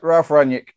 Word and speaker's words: Ralf Rangnick: So, right, how do I say this fellow's name? Come on in. Ralf Rangnick. – Yeah, Ralf Rangnick.